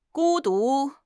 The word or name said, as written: 孤独